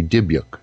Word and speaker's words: dybbuk: I know he pronounces it that way, but the Eastern European pronunciation would be Dybbuk.